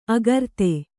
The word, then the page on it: ಆಕೃತಿ: Kannada